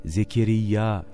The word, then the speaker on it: Zekeriya: Zekeriya aleyhisselam. Zekeriya adı nereden geliyor diye biraz araştırınca görüyorsunuz ki kelimenin aslı İbranice'de Zekarya diye geçiyor. İslami kaynaklarda yine kelimenin İbranice olduğu Zekeriyyü veya Zekeriya şeklinde telaffuz edildiği belirtiliyor.